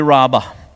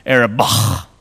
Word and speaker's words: Arabah: It goes down into the Araba. Or actually, I guess the Hebrew pronunciation would be the Arabah.